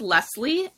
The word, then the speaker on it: Lesley: It's Coaching with Lesley. And my name is spelled L-E-S-L-E-Y.